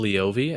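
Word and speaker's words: Leovy: is it Jill Lavoie who wrote the book? – Jill Lavoie, and that's how I've been pronouncing it.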